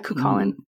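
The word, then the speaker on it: Cúchulainn: It's spelled C-U-C-H-U-L-L-A-I-N-E, O'Reilly. – It looks like it's based on the old Irish folk hero, whose name is maybe pronounced something like Cuchulain, but I... – Yeah, Cuchulain.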